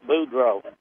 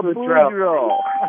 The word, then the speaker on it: Boudreaux: There's a product that's made down in, I think you pronounce it Buford. Is that correct? I mean, if you spell it B-O-U-N-D-R-E-A-U-S, what is that? How do you people from Tennessee say that? – That's Boudreaux. – Oh, Boudreaux.